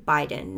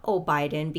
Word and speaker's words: Biden: It's President Biden, not O'Biden, because I keep mixing it up with Obama, even though they're one and the same.